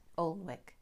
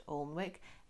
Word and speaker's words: Alnwick: So Alnwick is spelt A-L-N-W-I-C-K, Alnwick, yeah? But it's not pronounced Alnwick, it's pronounced Alnwick. You pronounce neither the L or the W.